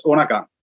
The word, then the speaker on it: Undergang: It's not really that easy, I guess, for people not speaking the Danish thing, which is pronounced like it's two N's, so it's Undergang.